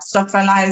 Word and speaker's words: Stokfella: Stockfella is spelled Stokfella.